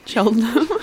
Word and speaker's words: Cheltenham: How do you say it? – Cheltenham. – Cheltenham. I said Cheltenham before and that was bad.